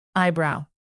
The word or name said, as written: eyebrow